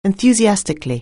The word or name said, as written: enthusiastically